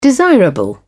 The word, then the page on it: desirable: British English